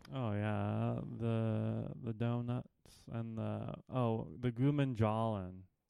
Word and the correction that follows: gulab jamun: Is that how you say it? – Gulab jamun. – Gulab jamun, yeah.